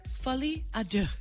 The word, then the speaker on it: Folie à Deux: Hey, there's an official sequel on the way for the movie movie Joker called Joker Folie à Deux. Did I say that right, Peter? – Sure. Okay, good.